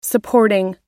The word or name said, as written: supporting